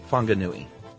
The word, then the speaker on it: Whanganui: Or the town of Whanganui is pronounced Whanganui.